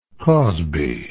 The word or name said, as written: cosby